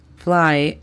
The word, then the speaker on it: fly: La pronunciación fonética de esta sería C-A-T, cut. El segundo verbo es fly. Fly significa volar. La pronunciación fonética de este sería F-L-A-I, fly. El tercer verbo es give. Give significa dar. La pronunciación fonética de este sería G-I-E-V, give.